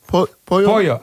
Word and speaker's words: pollo: Pollo.